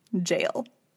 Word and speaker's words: gaol: So it's spelled G-A-O-L, right? It looks like goal. – Yes. – Right? Okay. Ready? You ready? Jail. – Seriously? – Yes. It's pronounced jail. It's just a soft G instead of a hard G.